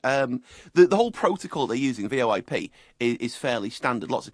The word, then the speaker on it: VoIP: Well, the generic would be Voice Over Internet Protocol, or I guess you could pronounce it as VoIP.